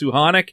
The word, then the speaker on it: Suchanek: You call them Thomas Suchanek. I know at the World Juniors that everybody was told to pronounce it as Suhanek.